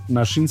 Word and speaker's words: Naxin: Nashin se escribe Naxin y es en mazateco, que significa venadito.